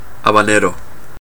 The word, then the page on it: habanero: Chile; /ˌabaˈneɾo/; [ˌäβ̞äˈne̞ɾo̞]